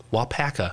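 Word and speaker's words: Waupaca: Wapoka. Wapaka. How do you say it? – Wapaka. – Wapaka. – We talked about this last night. Alpaca. Wapaka.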